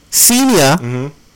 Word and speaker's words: Ksenia: Her name is Ksenia. – I don't think you pronounced the K. – Ksenia Ovchekinokova.